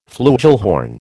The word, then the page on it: flugelhorn: American English